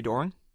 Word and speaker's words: Doering: When, how do you pronounce it? – I say Doering.